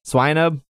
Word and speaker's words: Swinub: Is that how you say it? I can never say it. – Swinub. – Swinub. Oh, yes.